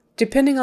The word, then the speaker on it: depending: Depending on the quality in January. Depending. The stress is on the middle syllable, depending.